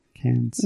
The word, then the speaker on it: Cannes: So, yeah, he died March 28th, 1927 in Cannes. Am I saying that right? France. Cannes.